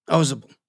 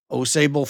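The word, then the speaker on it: Ausable: They pronounced the name differently. Mitchell Osable, three syllables. Otto Osable, one elided syllable, like the locals pronounced it.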